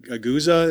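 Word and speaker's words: Gose: but then you did like a Guza, is that how it's pronounced? – Yep.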